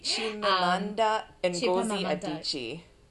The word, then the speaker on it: Chimamanda Ngozi Adichie: Chimamanda Ngozi Adichie.